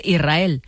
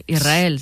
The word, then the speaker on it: Israel: Y la S con la R es difícil de pronunciar. La gente dice Israel, es sin verdad. Que suene la S. Israel, sí, es difícil de pronunciar.